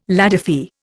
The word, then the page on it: ladyfy: Indian